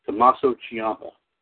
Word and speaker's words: Tommaso Ciampa: I know if I say this wrong, Tommaso Ciampa. – You got it right.